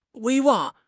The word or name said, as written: 威望